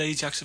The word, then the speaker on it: Ajax: I think it was Ajax of Amsterdam.